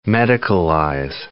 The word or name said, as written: medicalize